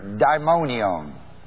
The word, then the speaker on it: daimonion: You see, there's a Greek word, daimonion, It's spelled D-A-I-M-O-N-I-O-N.